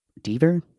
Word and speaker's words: Deaver: And Mason Dever is Deaver? It's spelled like Beaver with a D, so I guess Deaver is also non-binary, which is really, really cool.